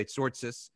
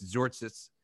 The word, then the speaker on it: Tzortzis: I'm trying to pronounce it properly, but people usually say Tzortzis, but it's Tzortzis for everyone who knows Greek.